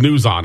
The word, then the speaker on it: NewsOn: The solution is found on your iPhone, and it's called News On, and I'll spell it. It's N-E-W-S-O-N.